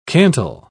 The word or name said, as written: cantle